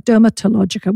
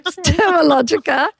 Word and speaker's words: Dermalogica: Because everyone started saying dermatologica. We said the T is silent. – Right, right. – Dermalogica.